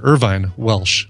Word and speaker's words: Irvine Welsh: The movie is Filth, and it's based on a novel by Irving Walsh, the guy who also wrote the book that became I'm sorry, Irvine Welsh, not Irving Walsh, Irvine Welsh. Seems appropriate for this segment to slightly mispronounce a name.